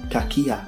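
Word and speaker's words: kakia (κακία): And the word maliciousness is pronounced also in the Greek kakia, which means badness or malignity, evil malice, naughtiness, wickedness.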